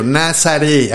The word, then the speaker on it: Nazaré: Nazaré, así tal cual como se escucha, con Z y e, acento en la E.